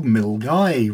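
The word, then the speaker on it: Milngavie: Last weekend we went up to Milgai, which isn't pronounced the way it looks.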